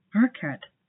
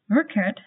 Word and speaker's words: Rückert: To be honest, although I speak German fluently, I'm not very good at pronouncing the German R. It's pretty annoying not to be able to pronounce your own name very well. Rückert